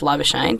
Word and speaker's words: Labuschagne: And I think so far you can say Marnus Labuschagne.